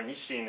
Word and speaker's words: Nissin: And then his son, who's now the CEO of Nissin, we call it Nissin is the English way, or Nishin is the Japanese way to pronounce the company.